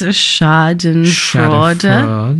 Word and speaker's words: schadenfreude: I think the word is schadenfreude.